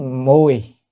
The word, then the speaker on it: Mùi: À, Kỷ Mùi. À, đúng không, cái chữ này nó thường chúng ta đọc là vị đó, nhưng mà thật ra nó là mùi, mùi trong năm tháng là phải mùi.